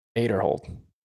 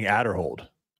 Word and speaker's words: Aderhold: Well, people said Ezra's name wrong for a long, long time. – Aderhold. – Yeah, but people were saying Adderhold for a really long time.